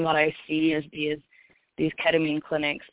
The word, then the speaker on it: ketamine: And then how can, I think it's called ketamine, I hope I'm pronouncing it correctly.